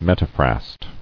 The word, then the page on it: Metaphrast: met·a·phrast; Met"a*phrast